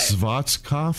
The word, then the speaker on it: Schwarzkopf: It's S-C-H-W-A-R-Z-K-O-P-F. – Now, he pronounced it to us as Schwarzkopf.